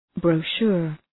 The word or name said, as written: brochure